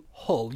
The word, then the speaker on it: Hull: if you come from Hull, you usually drop the H. So usually it's just Hull.